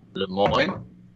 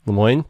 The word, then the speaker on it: Lemoine: He said it's pronounced the way it's spelled, and I don't know how to pronounce it, but it's Jody Lemoine. – Lemoyne. – Lemoyne. There you go.